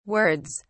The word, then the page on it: words: British English